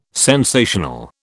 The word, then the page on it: sensational: British English